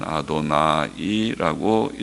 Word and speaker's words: 아도나이: 정통 유대교 서기관들인 마소라 학자들은 3절에 내 주여라는 말을 그러므로 하나님께 대한 호칭인 아도나이라고 아도나자가 긴 아자가 된 아도나이라고 읽었습니다.